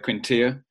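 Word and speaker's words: Quintillà: And it feels like we've got best of both worlds, actually, because Quintilla feels like he's the more defensive-minded. You're smiling at me, Mike, as if I pronounced that. – No, it's perfect. It was absolutely perfect.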